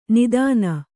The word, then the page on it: ನೂತ್ನ: nūtna